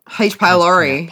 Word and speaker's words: pylori: H. pylori. Pylori. Yeah. Thank you.